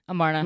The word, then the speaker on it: Amarna: Amarna.